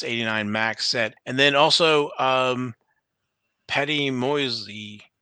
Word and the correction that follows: Moise: Patty Moise.